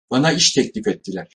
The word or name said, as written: ettiler